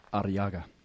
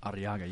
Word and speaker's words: Arriaga: Arriaga. – Arriaga. Ooh, there you go. – Arriaga. You got to roll your R's.